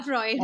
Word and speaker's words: Freud: So, so the gentleman took us through, uh, you know, the entire school of psychology that Freud, Freud, I don't know how to pronounce his name. Yeah, Freud.